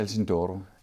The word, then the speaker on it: Alcindoro: som er øh, en af Rodolfos øh, venner, Øh, og, så, øh, og så er det øh, Alcindoro, er det sådan navnet udtales? Ja, Alcindoro.